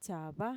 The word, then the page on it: txava: Mixtec